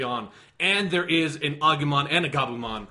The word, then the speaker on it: Agumon: We're talking about Digimon, and he Digivolved, and Agumon, and it's Agumon! – It's pronounced Agumon, and he's fucking awesome.